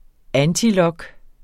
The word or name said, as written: antilog